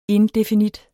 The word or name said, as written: indefinit